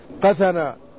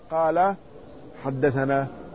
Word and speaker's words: قثنا: قثنا، لكنها لا تنطق هكذا وإنما تنطق قال حدثنا.